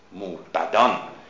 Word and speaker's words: موبدان: موبدان نه موبدان توجه کنید به تلفظ چون در محل قافیه به ما میگه که باید موبد تلفظ کنیم